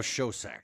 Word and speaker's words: Schoedsack: I was going to ask how to pronounce that. And Schoedsack? – His name is now Schoedsack.